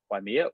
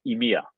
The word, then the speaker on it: Ymir: Now, am I saying that right, Ymir, or is it more French, Ymir? – Yeah, in French we say Ymir mining, but I guess the correct translation for English would be Ymir.